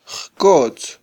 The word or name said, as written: jkotz